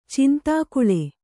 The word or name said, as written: ಚಂದಕಲ